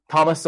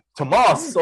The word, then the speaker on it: Tomás: Where they call him Thomas O'Leary. Tomás O'Leary, but you don't give a shit when you're named.